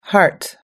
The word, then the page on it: heart: American English